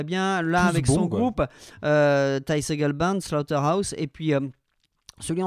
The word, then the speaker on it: Ty Segall: On prononce Ty, non pas Ty Segall, hein. C'est pas du Tergal non plus.